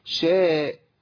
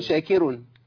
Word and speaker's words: شاكر: ومن تطوع خيرا فإن الله شاكر، خد بالك شاكر ما تنفعش، ولا شاكر ما تنفعش، الكاف نطقها كاف لا تنطق قاف، شاكر ما تنفعش، ولا خلي الراء مرققة أقول شاكر، هي شاكر عليم سبحانه وتعالى.